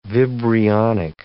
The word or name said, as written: vibrionic